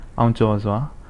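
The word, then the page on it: CHO CHO NWE: Burmese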